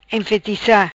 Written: Enfetica

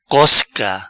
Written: Cosca

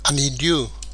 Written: Anidiu

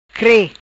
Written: Cre

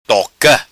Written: Toca

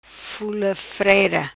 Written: Fula Freira

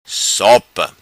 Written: Sopa

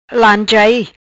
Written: Lanchai

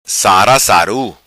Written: Sara Saru

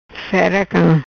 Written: Cam Fera

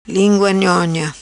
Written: Nhonha Lingua

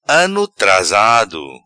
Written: Ano trasado

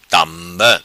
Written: Tamba

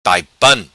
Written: Taipan

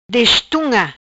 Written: Destunga

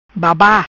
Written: Baba

